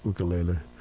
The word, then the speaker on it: ukulele: And they would pronounce that ukulele a little differently, like ukulele, because it's a Hawaiian word, and they spent years living in Hawaii with Youth with a Mission.